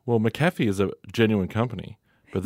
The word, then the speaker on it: McAfee: Is it McAfee? – McAfee, yeah.